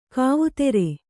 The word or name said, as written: ಕವಿತರು